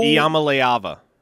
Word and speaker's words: Iamaleava: Iamaleava. – Iamaleava.